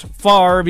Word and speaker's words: Favre: NFL legend Brett Favre, which is pronounced Favre, even though the V comes before the R, also listed in the lawsuit.